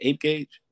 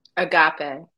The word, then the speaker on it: agape: So often we hear people saying, I want, is how you pronounce that ape gauge? – Agape.